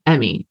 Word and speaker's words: Emmy: Like he said it M A, almost like the letters M and A, but it, he was saying my name, Emmy.